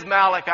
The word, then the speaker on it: Malachi: It's Malachi.